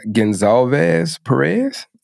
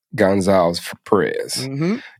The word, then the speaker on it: Gonsalves-Perez: So I'd like to introduce everybody to Dina Gonsalves-Perez. Did I say that right? – Yep. Gonsalves-Perez. – Gonsalves-Perez.